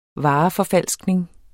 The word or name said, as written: vareforfalskning